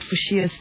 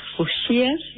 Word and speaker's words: fuchsia: So what are we learning about fuchsias today? – Yeah, it's actually quite strange. You pronouncing it fuchsia, I pronounce it fuchsia.